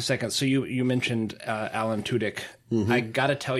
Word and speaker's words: Tudyk: It just doesn't feel right calling him Tudyk. – That's exactly how it's pronounced.